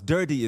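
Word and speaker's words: Durde: Durdy is his last name. That's how they pronounce it on YouTube. That's what I heard.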